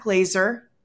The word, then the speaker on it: Glaser: We have a guest with us, Matt Glazer.